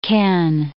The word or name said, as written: Kenn